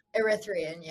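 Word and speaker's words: Erythraean: The Oracle that we are trying to free in this book is the Erythrian Sibyl. Really don't know if I said that right. Do we? Erythrian? – That was good. Erythrian, yeah.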